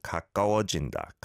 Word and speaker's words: 가까워진다: I don't think there's too much of a difference between the ka and ga, right? It's more that the ga kind of almost blends onto the previous syllable. 가까워진다.